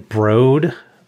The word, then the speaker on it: Brode: It's Ben Brode, Jared. – Oh, come on. Ben Brode. That's how big of a fan I am. – That's how big a fan you are. You correct pronunciations. I don't know why I always said Brody.